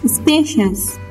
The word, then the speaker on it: spacious: The next word, لغت بعدی, spacious. Spacious. S-P-A-C-I-O-U-S. Spacious. The stress on the word spacious is on the first syllable. It means it is on S-P-A. خب فشار در لغت spacious در روی هجای اولی یا روی S-P-A است.